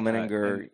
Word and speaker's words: Menninger: There's a famous quote that you've shared before about Dr. Menninger? How do you say that? – Yeah, name? – Yeah, Carl Menninger.